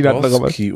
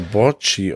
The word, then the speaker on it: Borsci: Nee, Martin Borski oder Borci ausgesprochen. B-O-R-S-C-I.